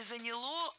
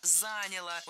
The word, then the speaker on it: заняло: Вот чтобы настроить карбюратор на хорошие пусковые качества для зимы, чтобы он ездил, то это заняло где-то пару дней работы на стенде. – Неплохо. – Нет, плохо, потому что не заняло, а заняло. Ударение не ставим на окончание ни в коем случае.